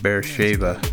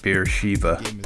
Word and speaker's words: Beersheba: And it's pronounced Beersheba, not Beersheba. Beersheba is, you know, some kind of Indian god of beer.